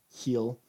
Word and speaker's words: Gil: If you're looking for him, his last name is spelled G-I-L, Heel.